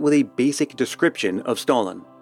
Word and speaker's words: Stollen: So far, you've heard me pronounce it a couple times as Stollen, and that's because I'm not going to embarrass myself with a failed attempt at affecting a German accent. For an authentic German pronunciation, I'll leave it to Louisa.